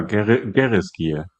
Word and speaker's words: Gereskir: Oder du hattest dir das nochmal angeschaut, wie man es ausspricht. Korrekt? – Ja, Gereskir.